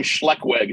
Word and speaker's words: Schleckweg: My name on my birth certificate is Raymond Joseph Schleckweg Jr. So my last name Schleckweg is two syllables. And it means bad way in German. So my name's Ray Badway.